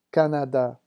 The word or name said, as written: Canada